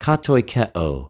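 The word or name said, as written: κατοικεω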